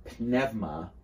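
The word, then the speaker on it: pneuma (πνεῦμα): And pneuma here, pneuma, is the way you pronounce it in modern Greek, ek pneumatosistin agiou.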